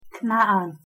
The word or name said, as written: כְּנַעַן